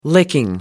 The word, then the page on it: licking: American English